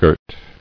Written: Girting

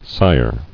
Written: Sired

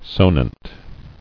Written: Sonant

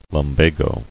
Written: Lumbago

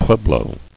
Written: Pueblo

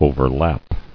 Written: Overlap

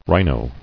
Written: Rhino

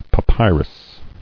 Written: Papyri

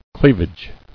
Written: Cleavage

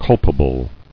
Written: Culpable

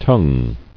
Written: Tongued

